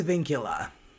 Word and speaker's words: Vincula: Did I say that right? Because I know you are the expert of all things English royalty. – I think it's Vincula. – All right, Vincula. There you go.